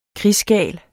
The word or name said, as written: krigsgal